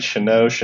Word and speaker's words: Chenault: Chenault, am I saying that right? Chenault. – Yep.